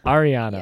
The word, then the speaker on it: Ariana: Ariana, yes.